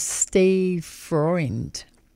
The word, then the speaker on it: Freund: We'll backtrack now to the Chicago singer-songwriter Steve Freund, is how you say it. It's actually spelled F-R-E-U-N-D.